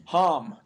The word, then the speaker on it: Homme: Is it Homme? – Homme. Josh Homme. – I've heard it so many times, like even on like professional radio people that should know this shit, they don't know how to say it. – Yo, Josh Homme. – I know, like Homme sounds more fun, but I always said it was Homme, but then apparently that wasn't it. Whatever.